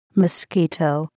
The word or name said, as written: mosquito